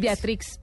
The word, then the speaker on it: Beatrix: ¿Se dice así? Beatrix. Beatrix, sí.